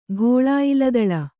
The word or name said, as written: ಘೋಳಾಯಿಲದಳ